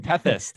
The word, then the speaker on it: Tethys: How do you pronounce it? Tethys. – Tethys, okay. I think, yeah.